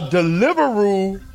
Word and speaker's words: Deliveroo: A Deliveroo driver, and I said that correctly, Deliveroo rider bites off all the shots customer's thumb.